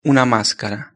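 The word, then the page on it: una mascara: Spanish (Spain)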